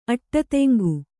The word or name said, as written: ಅಟ್ಟತೆಂಗು